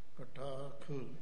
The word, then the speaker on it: कटाख: किवें उच्चारण किया है कटाख वो शुद्ध उच्चारण किया है तो इसे तरह ही करना